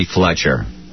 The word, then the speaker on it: Fletcher: This is Bobby Fletcher. – Hold on, honey. Say your last name. – F-L-E-T-C-H-E-R.